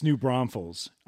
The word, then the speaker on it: New Braunfels: no but i was calling it new bronzefell or something okay. like that it's new braunfels okay.